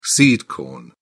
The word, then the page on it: seed corn: British English